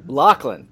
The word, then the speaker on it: Laughlin: Laughlin.